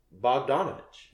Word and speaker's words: Bogdanović: Bogdanović.